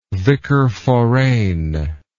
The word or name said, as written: vicar forane